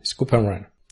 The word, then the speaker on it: scoop and run: ¿Cómo has dicho? – Scoop and run. – Scoop and run. – ¿No? ¿Se dice así? – Sí, scoop and run.